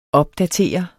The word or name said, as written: opdatere